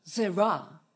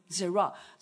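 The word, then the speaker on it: Zera (זרע): 在英文叫做 seed 种子， S-E-E-D 种子，种子。那希伯来文叫做什么？ Zera，创世纪里面的发音 Zera， Zera 的意思是什么？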